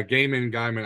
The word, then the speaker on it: Gaiman: It's Neil Gaiman, right? Neil Gaiman? – Yeah, Gaiman. I might have said it wrong.